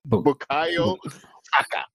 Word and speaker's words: Bukayo Saka: Seem to, yeah, as a Nigerian, I'm gonna insist that you all say right. His name right now. Bukayo Saka.